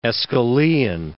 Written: Aeschylean

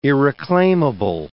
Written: irreclaimable

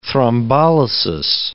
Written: thrombolysis

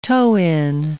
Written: toe-in

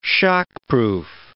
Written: shockproof

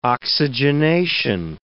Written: oxygenation

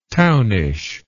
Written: townish

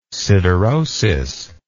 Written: siderosis

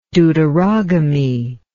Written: deuterogamist

